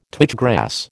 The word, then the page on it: twitch grass: American English